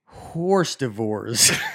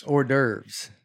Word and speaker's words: hors d'oeuvres: The worst word I butchered while reading out loud was horse divorce. – Hors d'oeuvres. – Yeah. I pronounce it as ours devours.